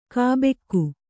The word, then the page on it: ಗಬ್ಬಿಗೆ: gabbige; Kannada